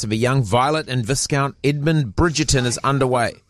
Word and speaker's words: Viscount: Viscount. – It's got an S in it. Viscount. It's like discount. Put it with a V. – It's like the S in disco. You don't pronounce it.